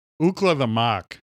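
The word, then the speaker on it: Ookla the Mok: Ookla the Mook? – Ookla the Mock.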